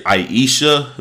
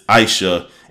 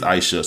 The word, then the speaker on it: Aisha: I've been hearing people say Aisha, but someone on Twitter broke it down that it's not Aisha, it's Aisha. And when they said in the shows, okay, it is Aisha.